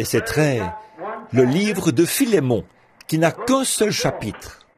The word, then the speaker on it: Philémon: Donc, quelqu'un a dit, là-bas, au fond, Philémon, ce qui, je pense, est vraiment la bonne prononciation.